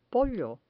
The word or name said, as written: pollo